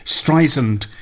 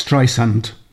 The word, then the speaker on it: Streisand: I don't suppose for a moment she listens to The Sentimental Journey, but she'll be cringing now because I've pronounced it Streisand. She says it's Streisand.